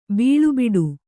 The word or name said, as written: ಬೀಳುಬಿಡು